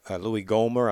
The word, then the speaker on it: Gohmert: I think it's Louis Gohmert. I might be mispronouncing his name.